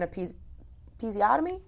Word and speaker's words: episiotomy: give her an episiotomy? – Yeah. – Did I say that correctly? – You did.